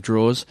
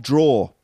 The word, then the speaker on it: drawer: And it's spelled D-R-A-W-E-R. – But you could just spell it D-R-A-W. – You could. – Draw. – Because draw a picture with with a pencil. It's the same sound. Draw. It's not drawer. It's just draw.